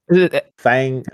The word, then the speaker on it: Fangorn: So you pronounce this Fangorn? I've always thought it was Fangorn. – I'm looking at a map. I don't know. Fangorn? – Fangorn. – The G is in the middle. It's F-A-N-G-O-R-N.